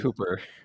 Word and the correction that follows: Hopper: Wait, Dennis Hopper?